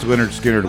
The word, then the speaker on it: Lynyrd Skynyrd: Pronounced Lynyrd Skynyrd was their first album in 1973.